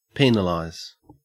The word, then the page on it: penalize: Australian English